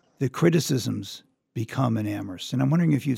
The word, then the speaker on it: Amherst: Amherst, people like to joke, is the place where only the H is silent.